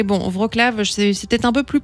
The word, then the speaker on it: Wrocław: Donc ça se prononce Wrocław. Je m'étais complètement planté en début de semaine. – Alors, hein. – On a entendu, hein, on avait écouté la prononciation. Je crois que c'est même Wrocław, je pense.